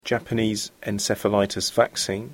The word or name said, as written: Japanese encephalitis vaccine